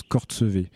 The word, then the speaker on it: Courchevel: Et le nom de Courchevel, en fait, il est tiré d'un lieu-dit qui s'appelle Courchevel, mais qui lui-même, en patois local savoyard, euh, se prononce Cortsevé.